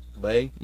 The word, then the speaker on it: les: Isn't it pronounced Lay, even Lay? Though it's got an S on it? – Yeah.